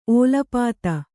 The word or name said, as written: ಓಲಪಾತ